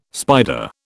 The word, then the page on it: spider: British English